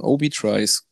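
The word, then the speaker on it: Obie Trice: Obi Tries. Google mal o Obi, schreibt man O-B-I-E und dann Tries, wie halt Tries.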